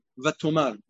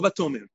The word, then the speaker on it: Vatomar: Vatan Esther Malka Vatomar. Lo Vatomer. Because over here, it's just the double Lashon.